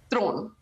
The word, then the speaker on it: Theron: Yes, because honestly, the truth of the matter is that it's not even remotely close to the real pronunciation. So can we hear the real pronunciation? – Theron.